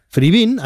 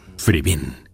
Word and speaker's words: Fribín: Fribín, así se pronuncia en su vídeo corporativo. – Fribín, gusto por la carne.